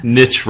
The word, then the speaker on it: niche: Niche rhymes with itch, okay.